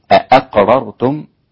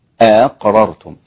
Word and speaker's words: أأقررتم: يبقى الهمزة الأولى نطقت بسيطة، هي أ، والثانية أقررتم، لكن لا تنطق بالتحقيق للهمزتين، أقررتم لا، أقررتم.